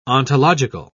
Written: ontological

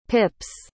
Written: pips

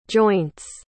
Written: joints

